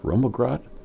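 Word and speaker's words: rømmegrøt: What is Romagrot? Am I pronouncing that right? – Romagrot is a Norwegian cream porridge.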